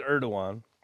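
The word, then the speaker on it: Erdoğan: Erdogan, Tayyip Erdogan, or as they pronounce it, Erdogan,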